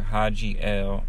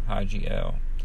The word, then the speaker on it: Hadji L: But with that just being said, though, I want y'all to check out my brother, Haji L. His name is spelled H-A-D-J-I-L, Haji L, on YouTube.